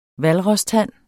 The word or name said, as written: hvalrostand